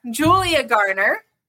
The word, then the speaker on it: Julia Garner: I want to say her name right because I said it wrong earlier. Julia Garner is going to be playing a female Silver Surfer in Fantastic Four.